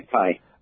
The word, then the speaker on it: Ajit Pai: did I say that right? – Yes, okay. Ajit Pai.